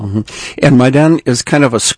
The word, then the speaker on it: Maidan: How do you pronounce it? – Maidan.